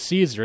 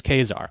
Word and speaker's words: Caesar: You know the Latins actually pronounced all the C's as K's. It's not Julius Caesar, it's Julius Caesar.